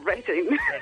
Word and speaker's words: Reading: Oye, lo pronunciáis mejor que lo de aquí, Reading.